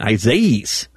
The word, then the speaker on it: Isaias: The E at the end of it is the big pronunciation, Isaias. It heads Isaias. Okay. That sounds like an AIA in the middle of it.